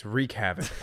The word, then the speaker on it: Reykjavik: Say it right. It's Reykjavik.